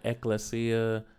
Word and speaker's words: ecclesia: Ecclesia? – Ecclesia. Ecclesia. – I think you pronounce it Ecclesia.